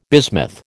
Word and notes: bismuth: American English

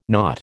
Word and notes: nought: American English

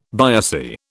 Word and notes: biopsy: British English

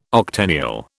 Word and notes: octennial: British English

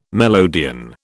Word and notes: melodeon: British English

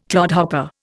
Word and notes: clodhopper: Indian

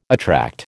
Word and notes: attract: American English

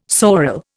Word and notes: sorel: Indian

